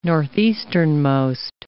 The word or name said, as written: northeasternmost